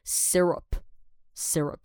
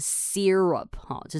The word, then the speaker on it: syrup: 好，再来呢，呃，要讲到我最喜欢的一个东西，但是最近比较少吃，因为要减肥了哈，是 syrup，syrup。好，那有些人呢会把它念成 syrup，哈，就是把那个 e e r 啊，就是念起来像 s e e r。 U P 这样 syrup 就是把那个 Y 拉长了。